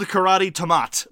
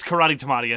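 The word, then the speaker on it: Karate Tomate: It's spelled Karate Tamat, but I don't think it's Karate Tamat, and I don't think it's Karat Tamat. I think it's Karate Tamati. I think that's how you're supposed to say it.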